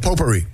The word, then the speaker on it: potpourri: Potpourri. Potpourri. P-O-P-O-U-R-R-I. – So close, but incorrect. – Was there a U in there? – There's a silent T in there. – P-O-T. – P-O-T. – What? A T? – P-O-T-P-O-U-R-R-I.